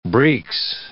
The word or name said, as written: breeks